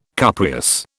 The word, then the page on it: cupreous: British English